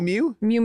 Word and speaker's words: Miu Miu: How do you pronounce it? Miu Miu? – Miu Miu. – M-I-U, M-I-U.